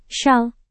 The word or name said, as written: syal